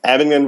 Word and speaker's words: Abingdon: So is the campus pastor at Elizabethan Campus and director of adult ministries at Highland Fellowship Church in Abingdon, Virginia. How did you pronounce that? I probably mashed that. – Nah, you did all right. Abingdon, Virginia. – Abingdon, Virginia.